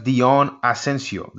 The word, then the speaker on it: Deon Asencio: So, ladies and gentlemen, Chef Deon Asencio.